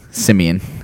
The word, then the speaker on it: Siemian: Uh, it's pronounced oh Siemian.